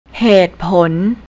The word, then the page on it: hatepon: thai